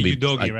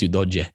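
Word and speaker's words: Udogie: It's not Udoge, right? – I think it's Udoje. That's certainly what that's how they pronounce it in Italy.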